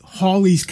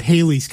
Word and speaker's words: Halley: It's like Hawley's Comet. – Yep. – The family apparently pronounces the name Hawley, not Halley's Comet.